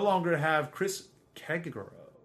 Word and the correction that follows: Jericho: I said that wrong. Chris Kegaro. You know, it's you know, he's back to being Chris Jericho, man.